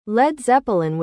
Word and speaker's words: Led Zeppelin: Page, seizing on the phrase, replaced balloon with Zeppelin and dropped the A from lead to avoid pronunciation confusion. Thus, Led Zeppelin was born, a name that perfectly captured the band's heavy, soaring sound.